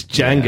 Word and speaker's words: Django: Take the oh, it's Django yeah. with a D. The D is silent.